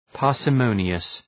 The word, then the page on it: parsimonious: {,pɑ:rsə’məʋnıəs}